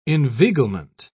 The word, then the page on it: inveiglement: British English